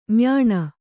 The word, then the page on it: ಮ್ಯಾನಾ: Kannada